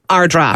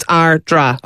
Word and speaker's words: Ardara: It's So it's spelt A-R-D. A-R-A. – Which is Ardara. – Ardara. – Yeah. – But it's pronounced Ardra. – Ardra. – So it's Ardra.